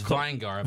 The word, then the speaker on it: Vingar: Close. It's Vingar. – Vingar. Okay. – I will Vingar. Take anything other than vinegar because it looks a lot like it. – So yeah, it's a V-I-E-N-G-A-R.